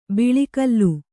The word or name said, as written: ಬಳಿಕೋಲು